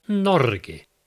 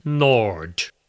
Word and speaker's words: Norge: She went for Norge, as in corgi or porgi. He went for Norge, as in George, forge or gorge. She was much closer.